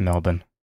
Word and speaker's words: Melbourne: Yeah, it's pronounced Melbourne. We know that. Melbourne.